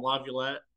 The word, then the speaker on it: LaViolette: That's actually pretty much spot on, LaViolette.